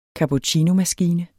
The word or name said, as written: cappuccinomaskine